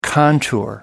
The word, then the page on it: contour: American English